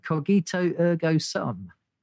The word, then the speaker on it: cogito ergo sum: Cogito ergo sum. – You got it.